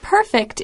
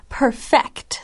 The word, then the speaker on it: perfect: This is not perfect. It's perfect. So the accent, the emphasis on those syllables is a little bit different.